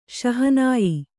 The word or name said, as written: ಶಹನಾಯ್